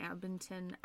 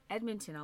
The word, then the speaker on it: Edmonton: She was born October 3rd, 1991, in Edmonton. I said that weird. You hear me? No, that's the right way. I said it wrong. Anyways, Edmonton, Alberta.